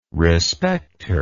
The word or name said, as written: respecter